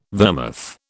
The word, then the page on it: vermouth: British English